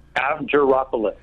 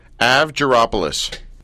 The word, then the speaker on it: Avgaropoulos: First of all, how do you pronounce Marie's last name? – Avgaropoulos. – Avgaropoulos.